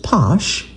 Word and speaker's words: Pox: One of the first commercial bottlings of Pox, I'm seeing it written P-O-X, but they pronounce it Posh, available in the United States.